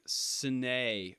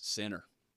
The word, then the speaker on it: Sinner: I always thought it was, like, Sine or something like that, like an Italian, yeah. Like, but no, it's Sinner. You got to pray for him, that Sinner.